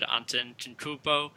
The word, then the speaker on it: Antetokounmpo: I was already high on the Bucks as well, as said Antetokounmpo.